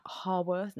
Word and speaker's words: Haworth: Those English things where I read it as I see it, and then like they'll be like, no, it's actually pronounced Haworth. – Haworth. Oh, that's how you pronounce get it.